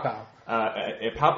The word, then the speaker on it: Pau Pau: Tahina Pau Pau. – Pau Pau, like I was telling Matt before, like two guns being fired.